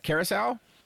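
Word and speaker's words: Curaçao: I put 1/2 ounce of orange Curacao.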